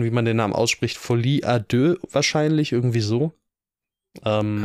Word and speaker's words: Folie à deux: Folie à deux klingt gut. Folie à deux. Offen also offiziell jetzt, äh, das ist die einzige richtige Aussprache.